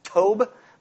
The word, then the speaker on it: tov: It's actually pronounced tov, but I like how it's actually written, tov.